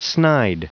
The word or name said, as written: snide